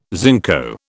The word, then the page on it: zinco: British English